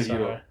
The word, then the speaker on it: Chytil: Chytil? – I wanted to mess it up, but okay, we'll say Chytil.